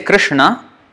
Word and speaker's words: Krishna: When we say Krishna, this is the na that we are pronouncing, or supposed to pronounce. It's not Krishna, na. It's na, Krishna. So that's how it's pronounced.